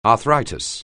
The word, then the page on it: arthritis: anglais